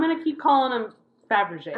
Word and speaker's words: Fabergé: I always pronounce it Fabergé. – Me too. – But that's actually wrong. It's Fabergé.